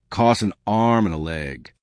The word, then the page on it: costs an arm and a leg: American English